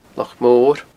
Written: Loch Mor

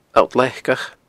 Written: alltleacach

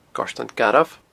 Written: Goirtean Garbh

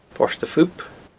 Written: Port a Phoop

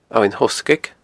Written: Abhainn Thoghsgaig

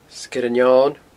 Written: Sgeir an Eoin

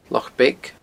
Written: Loch Beag

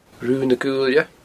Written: Rubha na Guailne

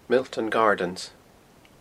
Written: Milltown Gardens

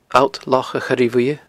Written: alltlochachoirebhuidhe

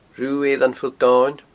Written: Rubh' Eilean Phuill Domhainn

